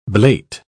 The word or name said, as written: blate